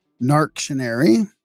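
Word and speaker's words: Narctionary: Narc.tion.Ary. I guess that's how you would pronounce it, right? – Yep. That's perfect.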